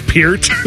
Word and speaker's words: Peart: And Neil Peart was the drummer of Rush for 40 years. It's Peart.